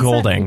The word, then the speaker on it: Goulding: Is that how you pronounce Goulding. – It? Goulding. – Goulding? Yeah, Ellie Goulding.